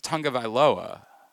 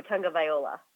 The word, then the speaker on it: Tagovailoa: Well, because we know his brother, and his brother told me it was Tagovailoa. – Oh, Tagovailoa.